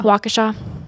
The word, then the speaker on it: Waukesha: And so then the Waukesha. That might be wrong. – No idea. – County police. – I think it's Waukesha. – Waukesha. You are right. That is that is correct.